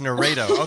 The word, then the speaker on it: Narrato: Have I been saying it wrong? It's Narrato. Okay, cool. Like, narrate.